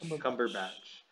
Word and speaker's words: Cumberbatch: Cumberbatch. Cumberbatch.